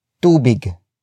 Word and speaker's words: tubig: That would be tubig.